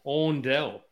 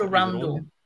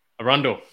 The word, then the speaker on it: Arundel: Case study duplex in Arundel. I don't know which. – It's called Arundel. – Arundel, there you go.